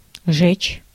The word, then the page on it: verbranden: /vərˈbrɑndə(n)/